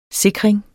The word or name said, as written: sikring